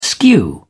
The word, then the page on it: skew: British English